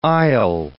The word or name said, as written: isle